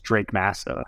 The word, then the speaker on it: Drake Massa: My name is Drake Massa.